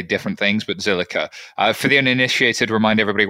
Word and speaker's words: Zilliqa: And of course, last but not least, making a debut today is Max Cantella, who's co-founder of, I never know how to say this, Zilla? Is it just Zilla? The Q always confuses me. – Zillica. – There we go.